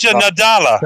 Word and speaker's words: Satya Nadella: Well, according to Zadja, whose name I can never pronounce, the head of Xbox or Microsoft. – Satya Nadella.